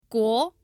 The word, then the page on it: guo: 2